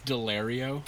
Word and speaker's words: Daulerio: You're like 99% there. It's Delario. – Delario. – But Delario is what like most people say.